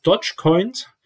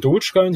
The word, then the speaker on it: Dogecoin: Und zwar ist Dogecoin oder Dogecoin, wie man es aussprechen möchte, eine Spaß-Kryptowährung eigentlich.